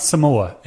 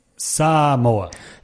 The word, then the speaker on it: Samoa: The first problem I have is trying to pronounce Samoa properly, Donny. It's not Samoa. It's Samoa.